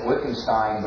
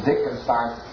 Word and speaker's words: Wittgenstein: It's spelled like Wittgenstein, but it's Wittgenstein. That's just because they're German.